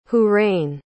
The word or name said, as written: hoorain